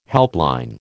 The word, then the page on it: helpline: American English